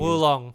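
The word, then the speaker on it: Wo Long: Oh, you know what I haven't tried yet, and I think it did just come out, is Wolong. How do you pronounce that, by the way? Because I know it's Chinese. – Wolong. – Okay, Wolong.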